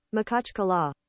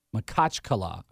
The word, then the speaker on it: Makhachkala: So here's what Google says the pronunciation of the city in Russia is. – Makhachkala. – Makhachkala. I was so off.